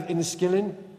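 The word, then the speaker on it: Enniskillen: Remembrance Day, 1987, the Northern Irish town of Inniskillen. Have I said that correctly? How much? See, there you go. Exactly like I said it.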